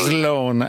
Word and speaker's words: Sloan: John Peter, perdonami la pronuncia del tuo cognome, già quella S lì non so da quale fare, la mosca o il serpente, cosa devo fare? – È il serpente. – Sloan, eccolo. Sloan.